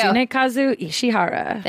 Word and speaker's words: Tsunekazu Ishihara: Tsunekazu Ishihara.